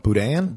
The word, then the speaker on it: boudin: And then I still mispronounce it, even though I love Cajun food. Bodine. – Oh, boudin? – Boudin. Still always miss it. Boudin. I believe that is how you pronounce it.